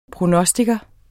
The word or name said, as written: prognostiker